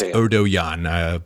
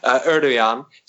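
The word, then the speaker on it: Erdoğan: It's even Erdogan. I just wanted to point out that it's Obi-Wan. It's Erdogan. People told me about that. So the G, is silent.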